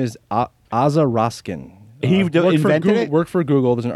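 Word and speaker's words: Aza Raskin: The way I remember Aza Roskin's name, so you guys remember it, is it rhymes with Baza Poskin.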